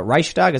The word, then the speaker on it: Reichstag: And the funny thing is they're marching next to the Reichstag, is that how you pronounce it? – Mm-hmm. Yeah.